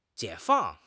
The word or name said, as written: 解放